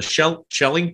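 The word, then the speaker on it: Schelling: And this kind of decentralized system provides protections against that, because everybody, if you're going to make a change from the shelling point, am I saying that right? – Yes.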